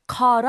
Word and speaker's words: caught: 그래서 과거형이 돼서 아까 take it easy를 took it easy라고 한 것처럼 catch up on이란 말을 caught up on이란 말이 한 거죠. – Perfect. – caught, C-A-U-G-H-T, caught라고 발음하게 되고요.